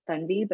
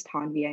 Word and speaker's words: Tanvi: And the correct pronunciation of my name is actually like Thunvi, but to me that's a very personal identifier for my parents talking to me. And even though it is technically the correct standard pronunciation, I for me, it's a very personal identifier of my family and my parents when they talk to me.